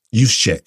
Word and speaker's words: Juszczyk: Juszczyk. – Thank you. Kyle Juszczyk, I think it was him, came out and said they didn't know the rules.